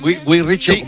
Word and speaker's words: Guy Ritchie: Guy Ritchie.